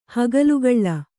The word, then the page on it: ಹಗಲುಗೊಲೆ: kannada; hagalugole